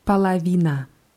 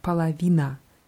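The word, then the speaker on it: Palavina (половина): Die Hälfte heißt Palavina. – Palavina. – Ja, mit zwei O's vorne geschrieben Polowina, werden aber beide WAs ausgesprochen, Palawina.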